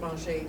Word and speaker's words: manger: Well, I can tell you because I'm French, and that means manger. The word pronounced manger.